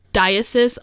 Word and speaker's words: diocese: Diocese of Rockville Center." – Archdiocese? – Is that how you say it? – It's diocese, yeah.